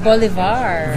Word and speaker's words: Bolivar: How do you say her last name? – Bolivar. – Bolivar. That's it. It rings a bell. – Like Simon Bolivar.